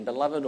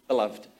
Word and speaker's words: beloved: Because this one word is the gospel is expressed in two or three syllables, depending how you say it, beloved or beloved.